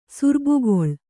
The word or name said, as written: ಸುರ್ಬುಗೊಳ್